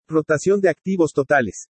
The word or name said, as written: ROTACION DE ACTIVOS TOTALES